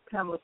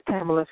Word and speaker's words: Pamela: This is Pamela from New York. How you doing? – I'm doing so wonderfully well. Can you say your name one more time? – This is Pamela from New York.